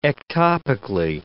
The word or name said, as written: ectopically